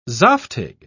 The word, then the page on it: zaftig: British English